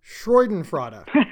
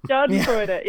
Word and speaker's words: Schadenfreude: and I'm gonna I'm gonna butcher the word I always do, Freudenfreude. Yep, sure did. Schadenfreude.